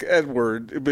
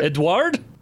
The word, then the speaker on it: Edouard: E-D-O-U-A-R-D. – Edward? – It's kind of like Edward. Is that how you say it? – Edward? – That's probably closer.